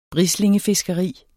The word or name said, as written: brislingefiskeri